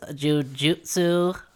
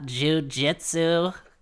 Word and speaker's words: jujutsu: And, hey, excuse me, it's actually pronounced jujutsu sorcerers. It's not jujitsu.